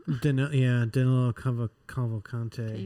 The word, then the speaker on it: Danilo Cavalcante: Danilo Cavalcante.